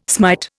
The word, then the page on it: smite: Indian